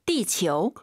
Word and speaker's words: 地球: 지우하지 마시고 아유 사이에 오 넣어서 지구 발음 다시 한번. – 地球.